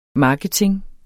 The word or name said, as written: marketing